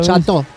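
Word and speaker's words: château: O sea, E-A-U es O, con acento. – Ouauro. – Es la como chato. No se escribe chato, se escribe chateau.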